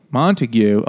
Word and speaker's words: Montague: Montague.